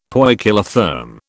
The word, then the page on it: poikilotherm: British English